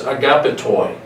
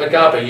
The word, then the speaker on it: agapetoi: But the word there for friend in the original language is agapetoi, which sounds like the word, and you've heard this word all your life, agape. Agapetoi, agape. You see the connection.